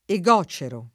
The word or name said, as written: egocero